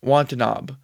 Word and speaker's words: Watanabe: Devin Booker, Bradley Beal, Josh Okogie, Kevin Durant, DeAndre Ayton, Campaign, Jordan Goodwin, Damian Lee, Kante Bates-Yap, Yuta Wampa, Yuta Watanabe.